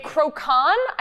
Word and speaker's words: croquant: A croquant. I think that's how we established it's pronounced.